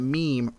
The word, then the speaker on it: meme: A is remember, or sorry, part two is remembering the pronunciation, and step one A is remember that meme rhymes with words like team and beam, and it gives a bunch of words that are that all sounds the same.